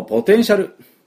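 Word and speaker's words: potential: ではこのポテンシャル行きましょうか。で、このポテンシャルのですね、発音、いくつか気をつけなきゃいけないところありますね。で、まずピーの音を昨日やりました。スイカの種を飛ばす。プッ、プッ、プッ、プッ、プッ。で、ポテンシャルは、ポテのところに、アクセントがつきます。ポテンシャル。ポテンシャル。っていうふうに発音しますね。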